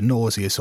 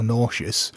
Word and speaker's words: nauseous: I mean, I keep hearing it pronounced differently as either nauseous or nauseous. Nauseous, I think, seems to be much more of an American pronunciation.